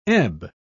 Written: ebb

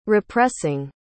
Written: repressing

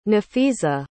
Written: nafeeza

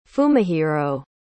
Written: fumihiro

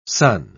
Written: son

